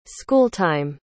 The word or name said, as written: schooltime